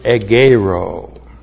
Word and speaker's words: egeiro: The conjunction chi is connective, correctly translated and then we have the verb egeiro. E-G-E-I-R, long O. Egeiro.